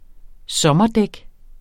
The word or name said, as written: sommerdæk